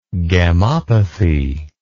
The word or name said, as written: gammopathy